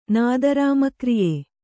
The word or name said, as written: ನಾದರಾಮಕ್ರಿಯೆ